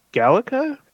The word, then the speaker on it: GALECA: Also in 2021, he received, I believe this is pronounced a Galeca, G-A-L-E-C-A.